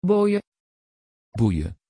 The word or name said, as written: boje